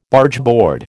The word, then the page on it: Bargeboard: American English